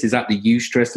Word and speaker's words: eustress: So you have, so when you say stress, this is distress, because this is this word eustress. Is that, I don't know if that's how you pronounce it. It's eustress. So distress is the bad one, you know, not eustress, which sounds like a Brexit thing.